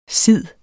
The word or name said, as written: sid